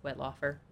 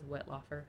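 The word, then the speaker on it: Wettlaufer: Her name was Elizabeth Wootoff. I can't say her last name. It says it's pronounced Wetlaufer, Elizabeth Wetlaufer, who murdered elderly patients in the nursing homes where she worked.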